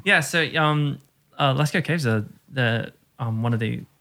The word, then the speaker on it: Lascaux: L-A-S-C-A-U-X.